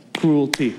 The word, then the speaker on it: cruelty: Cruelty? Cruelty. There's only one syllable in that, right? Cruelty. 2 syllables. See, I learned that from 2nd grade.